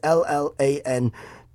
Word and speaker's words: Llanwrtyd: It's in Llanwadon, Wales, in Wales. I think that's how you say it. It's Llanwrtyd, Wales, in Wales.